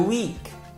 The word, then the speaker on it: week: During the week. Durante a semana. During the week. During the week. E só para deixar claro, week de fraco e week de semana tem exatamente a mesma pronúncia.